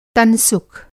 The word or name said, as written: तनसुख